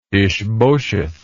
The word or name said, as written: Ishbosheth